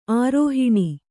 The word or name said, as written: ಆರೋಹಿಣಿ